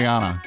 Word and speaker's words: Rihanna: Is it Rihanna or Rihanna? – It's Rihanna.